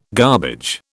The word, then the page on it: garbage: British English